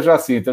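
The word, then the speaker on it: Jacinda: Comanda, ela, é, é, chama, é, é, é, Jacinta tem, uma, tem umas consoantes do meio ali do nome, mas você pronuncia Jacinta, esqueci o sobrenome.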